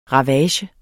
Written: RAVAGERNES

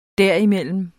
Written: derimellem

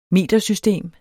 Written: metersystem

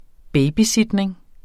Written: babysitning

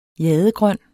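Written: jadegrøn

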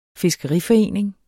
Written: fiskeriforening

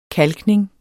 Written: kalkning

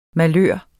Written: malør

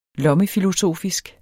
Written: lommefilosofisk

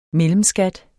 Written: mellemskat

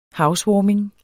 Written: housewarming

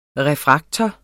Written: refraktor

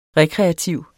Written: REKREATIVE

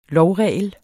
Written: lovregel

